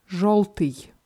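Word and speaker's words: жёлтый: Желтый heißt gelb und dieser Buchstabe Jo wird hier wie O ausgesprochen. Also die männliche Form ist Жолтый, die ähm, weibliche Form Жолтая.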